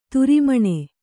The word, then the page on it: ತಿರುಮಣ್ಣು: kannada